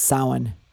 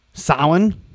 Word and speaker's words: Samhain: Real quick, I think it's pronounced Samhain. – Samhain? – Yeah. – Okay. All right. I like that. I think it's Samhain because I pronounce it like the... – It looks like Samhain. – And that's how Glenn Danzig pronounced it in his band.